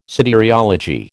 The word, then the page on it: soteriology: American English